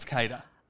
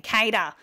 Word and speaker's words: CADA: C-A-D-A. For me, that's Kato. – I'd say so. But when I Googled it, it's a Spanish word that means every. So I imagine that the Spanish aren't working around saying Kato.